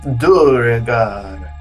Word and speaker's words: Duergar: Matt Mercer voiced the pronunciation on D&D Beyond for it, and he says it's Duergar. – Duergar.